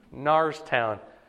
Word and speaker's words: Norristown: Narstown. – It's spelled Norristown. But he pronounces it Logic.